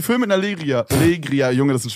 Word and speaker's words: Legria: Und das Ganze wurde mit einer Leria gefilmt. Die filmen 2022 noch mit einer Leria. Legria.